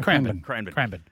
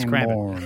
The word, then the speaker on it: Cranbourne: Cranbourne. But anyway, if you're American, you can say Cranbourne, but it's Cranbourne, like Melbourne. It's Melbourne, mate.